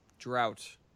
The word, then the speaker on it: drought: Drought. – Drought. – That is how you pronounce that word, David. – Draft. – No. – Drought.